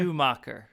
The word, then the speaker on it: Schumacher: Schumacher.